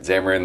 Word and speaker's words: Xamarin: Xamarin, like xylophone, I guess. – Yeah, I think that's how you say it.